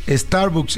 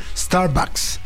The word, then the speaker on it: Starbucks: Lo decimos Starbucks y se pronuncia Starbucks. – Starbucks con A.